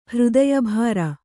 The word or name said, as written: ಹೃದಯಭಾರ